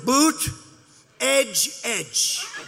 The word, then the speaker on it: Buttigieg: Buttigieg. Buttigieg. – And they said, here's what you do. Boot, edge, edge. Say edge like the edge of a building.